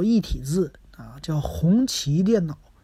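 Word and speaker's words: 宏碁: 不是宏基电脑，这个谢谢啊，嗯，这个东西呢，一直好像不是光我说错哈，我看好像，嗯，百度上很多人问都是打宏基电脑，这我一直都没注意，谢谢你啊，叫红旗电脑，其实也挺好记啊，和咱们红旗轿车发音是一样的，红旗电脑，啊，这位听友呢也非常的感谢，那再说一位啊，再说一位是咱们。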